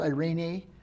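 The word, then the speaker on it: Irene (εἰρήνη): It sounds like a female name. Irene, the Greek word is Irene.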